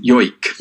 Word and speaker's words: joik: and it's spelt J-O-I-K. Can you pronounce it for us properly? – I don't know Sami, but the way that we normally pronounce it is yoik. – Yoik, okay. – So if you wrote it with a Y-O-I-K, then you'd probably get it pretty well.